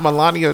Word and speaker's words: Melania: Before we continue, I heard that the lady he was with was not Melania Trump.